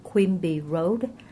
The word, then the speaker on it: Quimby: Tại cơ quan Vivo ở cái địa chỉ là 2260 Quimby Road. Quimby đọc là Q-U-I-M-B-Y, Quimby Road.